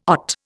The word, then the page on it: OT: Indian